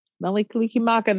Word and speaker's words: Mele Kalikimaka: Ah, is that Mele Kalikimaka? – That's the ticket. Mele Kalikimaka.